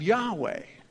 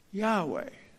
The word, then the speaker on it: Yahweh: In our Bibles, generally capitalized L-O-R-D, reflecting. It's sometimes pronounced this way in the Hebrew Bible, Yahweh. Yahweh.